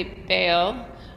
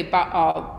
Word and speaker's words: Baal: And, it, you know, when you see the two A's together, and maybe in English you'd say Baal, but in Hebrew there's actually like a sound in the middle, or so that we say Baal, both A's are pronounced.